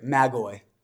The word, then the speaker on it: magoi: Now, when we think of these magi, and just for the fun of it, here's how you say it in Greek, magoi.